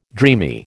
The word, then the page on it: dreamy: American English